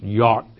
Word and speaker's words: yod: If you have your Bible, you'll have that J-O-D, which is pronounced yod in the Hebrew.